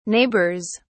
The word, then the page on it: neighbors: British English